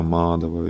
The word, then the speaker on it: Мадхава: Там ударение на третий слог с конца Мадхава.